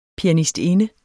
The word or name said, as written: pianistinde